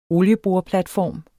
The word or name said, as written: olieboreplatform